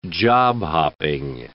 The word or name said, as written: job-hopping